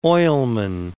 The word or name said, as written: oilman